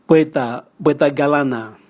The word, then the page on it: bweta bweta galana: Raga